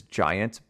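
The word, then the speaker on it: Jiant: It's, I believe it's Giant, but it's spelled with a J.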